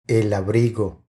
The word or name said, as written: El abrigo